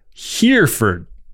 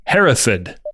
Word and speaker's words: Hereford: Sam, the Robert De Niro character, mispronounces Hereford, the location of the SAS's headquarters, as Hereford. The correct British way is Hereford.